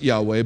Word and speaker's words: Yahweh: Americans today pronounce it Yahweh, but we're not quite sure how it's pronounced because the Jews, out of reverence for the name, stopped saying it.